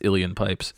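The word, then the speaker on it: Uilleann pipes: It's spelled weird. It's like UIL or whatever, but it's pronounced alien pipes.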